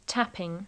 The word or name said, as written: tapping